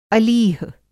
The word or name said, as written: अलीह